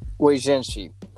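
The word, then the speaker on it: Oi, gente: Oi, Genshi. Oi, Genshi. That's like, what's up, people? That's how you say it in Portuguese.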